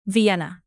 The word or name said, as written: vienna